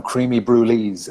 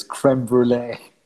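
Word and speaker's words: crème brûlée: We call them creamy brulees. I believe the correct way to pronounce it is creme brulee.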